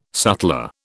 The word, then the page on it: sutler: British English